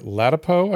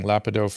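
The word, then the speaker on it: Ladapo: Tomorrow we have Dr. Latipo, I think is how we pronounce his name. I've been saying Lapido forever.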